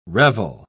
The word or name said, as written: revel